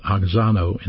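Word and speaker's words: oxano (αὐξάνω): It's pronounced oxano in the Koine Greek New Testament.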